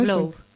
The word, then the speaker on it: Globe: Globe. – Globe, vale. La E no se pronuncia.